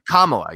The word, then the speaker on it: Kamala: Well, how is it pronounced? Kamala, right? – Kamala, yes.